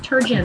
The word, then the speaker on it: Turgeon: It's like gin. It's like Turgeon.